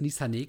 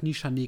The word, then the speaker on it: Nishane: Ich hätte jetzt auf Nishane getippt. – Ja, weil da ist nur ein H. Ähm, ne? which, deswegen würde ich auch Nishane aussprechen. Ja. Ähm, genau, und zwar der Ani. Ah ja. Drei kurze Buchstaben A-N-I.